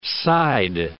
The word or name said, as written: side